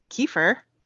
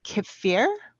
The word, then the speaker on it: kefir: Um and then I in the mornings I have a serving of, there's two ways to pronounce it, this I've heard. Um, I've heard kefir, as in Kiefer Sutherland. – Okay. – Um, and then I also have heard mm. kefir.